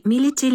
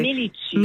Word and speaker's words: Миличи: Миличи ли точното произношение? Как е? – Миличи. – Миличи, точно така, да.